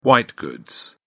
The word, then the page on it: white goods: British English